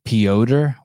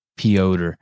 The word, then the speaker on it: Piotr: Piotr. When I wrote it down, I just write P-E-E hyphen Oder. O-D-E-R. Piotr.